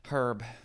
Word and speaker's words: herb: Herb. Herb is what's going on. Herb, not herb. – It's a silent H.